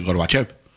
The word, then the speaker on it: Gorbachev: Bueno, cuando ves algún reportaje, cuando lo nombran, le dicen Gorbachev. Como si fuese con E, ¿no? – Sí. – Parece que en ruso. ¿No? La O se pronuncia como una E, Gorbachev, me parece.